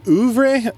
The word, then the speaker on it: oeuvre: Oeuvre, how do you say that? – It may be oeuvre, or it may just be oeuvre. – Oeuvre. – Like the Louvre.